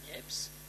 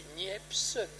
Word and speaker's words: Niepce: Mais moi j'ai des écrits dans ma famille, monsieur et madame Niepce, parce qu'on prononçait Niepce, le P n'était pas prononcé. Je pense que, alors je ne sais pas à partir de quand, moi j'ai toujours entendu le P prononcé.